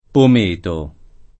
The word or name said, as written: pometo